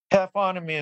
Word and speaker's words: taphonomy: And that's how it's said, right? Taphonomy or... – Taphonomy is correct, yes.